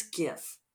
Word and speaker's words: GIF: It's GIF. All you GIF people, no. Graphic. Graphic is a hard G, therefore GIF is a hard G, and this is the hill I will die on.